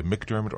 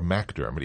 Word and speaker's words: MacDermot: Galt answers to either McDermott or MacDermott, even though it is spelled M-A-C-D-E-R-M-O-T with one T.